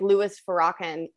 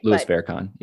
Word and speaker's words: Farrakhan: Well, I mean, Facebook has banned people who are, quote, more left-leaning, like Louis Farrakhan. I don't think I pronounced that right. – Louis Farrakhan.